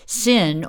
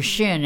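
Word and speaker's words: Sxin: The beatboxer's name is Sin or Shin, and it's spelled S-X-I-N. A lot of beatboxers do that. They add the X in there and then pronounce it with an S-H or just an S.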